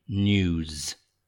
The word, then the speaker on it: news: It's always N-E-W-S, and that pronunciation, news. Get that z sound, news.